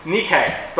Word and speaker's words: Nike: The actual pronunciation is Nike, but it's the same word.